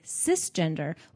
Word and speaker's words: cisgender: However, the correct word is actually cisgender, which is pronounced like sister, not cyber.